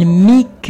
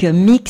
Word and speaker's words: Meak: Il s'appelle Mick. Mick, ça s'écrit M-E-A-K et il vous a chanté chaque saison.